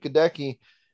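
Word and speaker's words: Goedeke: I've got Matt Waletzko at left tackle, and then Luke Gadecki. Is that how you say it? – Yes. – Okay. Yeah. Luke Gadecki.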